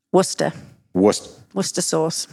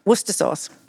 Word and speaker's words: Worcester: But so let me see if I have the proper pronunciation of this. So I say Worcestershire sauce. – Worcester sauce. – Okay. – Not Worcester sauce. – Yeah. Worcester sauce.